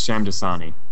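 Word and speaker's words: Shamdasani: I cannot pronounce that last name. – I'm assuming it's Shyamdasani. – You got it. You nailed it.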